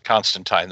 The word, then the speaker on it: Constantine: But if you like John Constantine's adventures, if you, like me, pronounce it Constantine the way his creator intended, if you're a big fat nerd for this character, it's definitely worth $6.99.